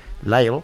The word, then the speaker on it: Lyell: Y bueno, pues ella, Lyle, se llama, se escribe L-Y-E-L-L, Lyle.